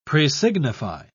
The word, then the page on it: presignify: British English